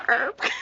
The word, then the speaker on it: herb: Yeah, and I always don't know if I'm supposed to say herb or herb. – It's herb. The H is silent, girl.